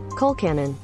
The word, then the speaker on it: Colcannon: Coal cannon. – Yeah, I said it right. Coal cannon. It's coal cannon.